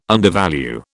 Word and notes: undervalue: British English

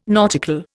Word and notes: nautical: Indian

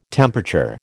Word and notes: temperature: American English

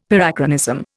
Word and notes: Parachronism: Indian

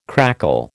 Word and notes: crackle: American English

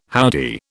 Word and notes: howdy: British English

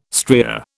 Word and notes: stria: British English

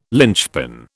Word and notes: linchpin: British English

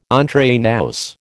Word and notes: entre nous: American English

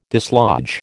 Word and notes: dislodge: American English

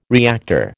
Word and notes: reactor: American English

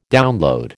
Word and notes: download: American English

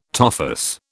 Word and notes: tophus: British English

